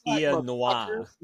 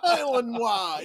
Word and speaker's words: Illinois: It's pronounced Illinois. – Illinois. – Yeah, that's right.